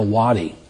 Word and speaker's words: wadi: W-A-D-E. We would pronounce it Wade, but it's a wadi.